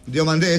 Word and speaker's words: Diomandé: Diomandé, ese sí, sí es Diomandé.